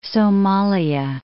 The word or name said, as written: Somalia